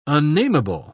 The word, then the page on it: unnamable: British English